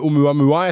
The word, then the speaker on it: Oumuamua: Men hva vet man nå da om Oumuamua etter at man har Var det riktig uttale?